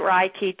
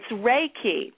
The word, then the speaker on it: Reiki: And in Japanese, it's more like Leiki because the R's become L's. But in English, it's just pronounced Reiki. If you think of, it's spelled R-E-I-K-I, but if you think about a ray of sunshine, then you can get started the right way.